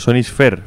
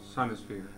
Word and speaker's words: Sonisphere: A ver, lo voy a decir yo, Sonisphere Festival. ¿Está mejor? – Sonisphere.